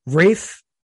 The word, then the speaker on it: Ralph: How do you know it's Rafe Fiennes?" Because I looked it up. I'm like, "It's Rafe Fiennes." So his first name is Rafe. Like it looks like Ralph, but it's Rafe.